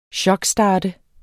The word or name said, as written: chokstart